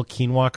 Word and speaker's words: quinoa: If you said to me, I'll give you $10,000 if you can spell quinoa correctly, I would lose $10,000. I couldn't spell it if it saved, to save my life. – Q-U-I-N-O-A? – Yeah.